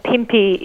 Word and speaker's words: Pimpi: And Pimpi is how you pronounce it. Well done.